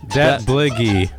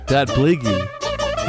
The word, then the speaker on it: Datblygu: Ah, how do you say that? The Welsh band? – Datblygu. – Datblygu gave us Mind. – Correct.